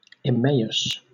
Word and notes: Emmaus: Southern England; /ɪˈmeɪ.əs/

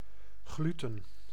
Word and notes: gluten: American English; /ˈɡluːtən/; [ˈɡluːʔn̩]

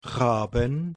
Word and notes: Raben: /ˈʁaːbn̩/

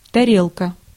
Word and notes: тарелка: /tɐˈrʲelkə/; tarelka

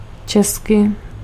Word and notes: tchèque: [tʃɛk]; France

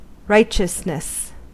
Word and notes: vertu: France; [vɛʁ.ty]

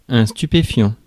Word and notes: drug: British English; /dɹʌɡ/; American English; [d͡ʒɹʌɡ]

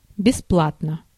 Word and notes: бесплатно: /bʲɪspˈlatnə/

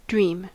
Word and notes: wünschen: /ˈvʏn.ʃən/

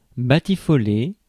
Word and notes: batifoler: France; [ba.ti.fɔ.le]

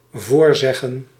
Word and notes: voorzeggen: /ˈvoːrˌzɛ.ɣə(n)/

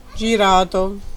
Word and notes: turned: American English; /tɝnd/

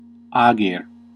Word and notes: land: /lænd/

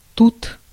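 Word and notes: mûre: Canadian French; /myʁ/

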